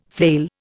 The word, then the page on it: flail: Indian